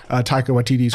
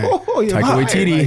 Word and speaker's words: Taika Waititi: So I imagine Taika Waititi's gonna... Did I say it right? – Yeah. – I'm killing it today. – Taika Waititi.